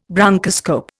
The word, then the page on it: bronchoscope: Indian